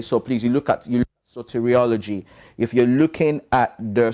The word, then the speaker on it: soteriology: But also from a theological viewpoint, when you're looking at the foundational doctrines, there is a branch of theology, basically known as soteriology. Okay, it's known as soteriology. So it's spelled S-O-T-E-R-I-O-L-O-G-Y.